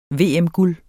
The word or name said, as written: VM-guld